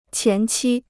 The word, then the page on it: 前期: qián qī